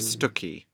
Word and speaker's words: Stiffkey: Remember the Vicar of Stuckey? – Harold Davison. – Yes, it's spelled Stiffkey. It's a part of Norfolk.